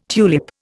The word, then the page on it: tulip: Indian